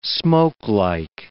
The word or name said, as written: smokelike